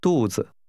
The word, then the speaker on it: 肚子: 肚子。子。肚。肚子。肚子。 – 肚子。 – Is pronounced with a falling and a neutral tone.